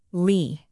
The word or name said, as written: leigh